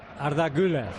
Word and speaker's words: Güler: Arda Güler.